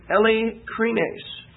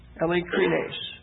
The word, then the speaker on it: eilikrines: The word for sincerity here in 1 Corinthians is eilikrines, eilikrines, eilikrines. That last E there is a long E, eilikrines.